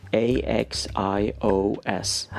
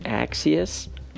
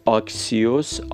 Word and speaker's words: Axios: A X I O S